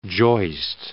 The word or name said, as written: joist